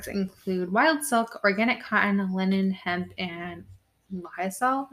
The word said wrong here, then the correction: Lyocell: Am I saying that right? – Lysol? – Yeah. Lysol? – I don't know what that is. – Lyocell.